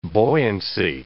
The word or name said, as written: buoyancy